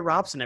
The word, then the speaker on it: Robson: You don't hear Robson everywhere, but you hear it butchered everywhere you go.